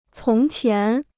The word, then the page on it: 从前: cóng qián